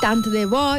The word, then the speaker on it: tant de bo: Tant de bo, i ser tant amb la T final, encara que en alguns llocs no la pronunciem, jo no la pronuncie, però seria tant de bo, ho haguera sabut abans.